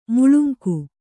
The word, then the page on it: ಮುಳುಂಗು: muḷungu